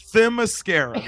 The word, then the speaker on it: Themyscira: Oh, like mascara. – Yeah, Themyscira.